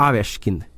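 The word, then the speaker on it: Aveskin (Овечкин): Det heter ju bland annat Ovechkin säger vi i Sverige. Han heter ju Aveskin. Mm. Ska uttalas egentligen då.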